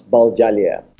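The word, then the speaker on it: Baljalia (保加利亚): So instead of saying Bulgaria, we say Baljalia.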